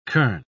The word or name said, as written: kern